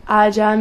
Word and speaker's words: Aja: Is it pronounced Aja? – Aja, maybe. – The J is an H sound.